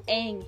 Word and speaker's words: Aang: It bothered me the whole movie because Aang, A-A-N-G, Aang, Aang is pronounced Aang.